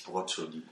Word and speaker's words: Brokkoli: Ich mag halt einfach Brokkoli. Zu Es spricht sich Broccoli.